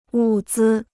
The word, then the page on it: 物资: wù zī